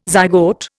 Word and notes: zygote: Indian

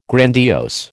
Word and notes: grandiose: American English

